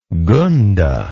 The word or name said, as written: gunda